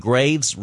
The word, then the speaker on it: Graves: Wines from Graves region of Bordeaux, French pronunciation is grave and means gravel, but you can intro with English pronunciation.